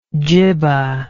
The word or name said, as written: djibbah